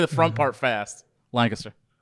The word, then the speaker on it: Lancaster: We're in Lancaster, Pennsylvania. – Close, Bruce. Close. – Oh, shoot. – We pronounce it Lancaster. You got to say it like you're sneezing. – We're in Lancaster.